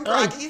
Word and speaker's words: Bragi: Yeah, Bragi.